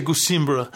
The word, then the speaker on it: Gusimbura: But he and you introduce the sort of thematic word early on, this Gusimbara. – Gusimbura. It's actually Gusimbura.